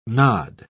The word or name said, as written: nod